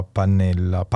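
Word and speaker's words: Panella: scriveva Pannella, si pronuncia così.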